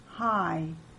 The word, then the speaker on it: high: GH is silent in the middle and at the end of words. After I, GH makes the I long. High.